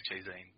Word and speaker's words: ChiZine: Well, moving on to something that I've read recently, in Dead Americans and Other Stories from, is it Cheezine or Chizine? – I think it's Cheezine. I've never heard anyone pronounce it because, I mean, they might call it Chizine, but I think it's Cheezine. Anyone I've heard pronounce it says Cheezine, so.